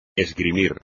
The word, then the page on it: esgrimir: espagnol